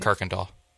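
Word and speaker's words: Kuykendahl: This person said, for me, it's how they pronounce Kirkendale. Did I get it right? I did. Good boy, Paul. Good boy. Right? I did get it right, right? – Kirkendall. – Damn it. Damn it.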